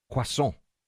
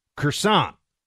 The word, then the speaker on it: croissant: When you go into any kind of a bakery and you want to order that crescent-shaped, very buttery pastry that the French call a croissant. Americans say croissant.